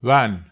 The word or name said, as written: wan